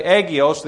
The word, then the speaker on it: hagios: but hagios. And I've heard some people say agios, the H being silent.